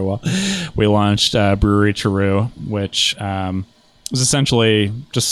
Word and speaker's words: Terreux: So you have the the the how do you say it the Tarot or yep. Uh, yep, Terreux.